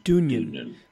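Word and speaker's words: Dungen: And they would be, you played Jungin, is that how you say it? – Dunjan.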